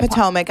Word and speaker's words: Potomac: Potomac.